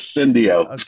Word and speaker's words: Syndio: Well, if you ask Greg Marshall, he used to pronounce it Sendeo, even though I told him it is Sendeo.